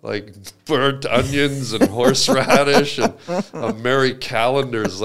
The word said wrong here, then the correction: Marie: Marie Callender's. You put some respect on that name and say it right. It's not Mary. What? It's Marie Callender's.